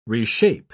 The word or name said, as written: reshape